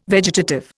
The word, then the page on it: vegetative: Indian